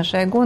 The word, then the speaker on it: Шойгу: Денис, когда, обратите внимание еще раз на эту фразу Глеба Павловского, что это показ не столько отношений с Шойгу, сколько, это по-разному ударение мы ставим все тут в этом эфире, но считается, что правильно Шойгу, но это уже кто во что гораст.